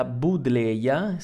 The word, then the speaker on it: Buddleja: E eh, si tratta della Budleia, si scrive con la, I, con la, mm, la J, e Davidi, e comunemente nota semplicemente come Budleia o arbusto pianta delle farfalle, perché attira tantissime farfalle.